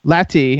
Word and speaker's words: Lati: Lati.